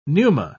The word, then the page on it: pneuma: British English